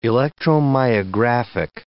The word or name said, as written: electromyographic